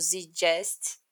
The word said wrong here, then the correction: Zeitgeist: eu não sei se eu falei certo, mas é. – O Zeitgeist.